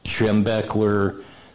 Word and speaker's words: Schembechler: Schembechler, how do you pronounce Schembechler.